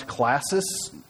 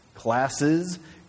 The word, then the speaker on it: Classis: And for you, you're going, did he mispronounce Classis? Classes? Classis is a group of regional churches from Northwest Indiana, Illinois, and I think as far as, Florida now.